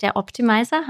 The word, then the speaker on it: Der Optimizer: Kleiner Hinweis, der Podcast heißt Der Optimizer, habe ich das richtig gesagt? Ja, richtig, ja.